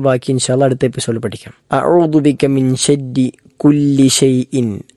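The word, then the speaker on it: അഊദു (أعوذ): ഒന്ന് അഊദു, അഊദു, അത് ശ്രദ്ധിക്കേണ്ടതായ മൂന്നക്ഷരങ്ങൾ. ഒന്നാമത്തെ അ അതെല്ലാവരും ശരിയാക്കാറുള്ളതാണ്, ആർക്കും തെറ്റില്ലാത്ത ഒരു കാര്യമാണ്, അക്ഷരമാണ്. രണ്ടാമത്തേത് ഐനാണ്, അഊ, അഊ എന്ന ഐൻ തൊണ്ടയുടെ മധ്യഭാഗത്തു നിന്നും പുറപ്പെടേണ്ട അക്ഷരമാണ്.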